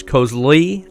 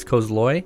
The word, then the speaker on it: Cosloy: Um, it's 12XU, run by uh, Gerard Cosloy, and I always mispronounce his name as Kozloy, I think, but it's Cosloy.